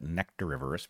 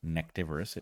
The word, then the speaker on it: nectarivorous: You should be careful to pronounce that nectarivorous because if you say nectivorous, it means they eat neckties, which they do not.